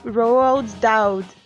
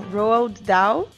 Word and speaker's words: Roald Dahl: baseadas nos livros do Roald Dahl. Não, Roald Dahl. Tá vendo? Nem sempre eu sei pronunciar as coisas em inglês.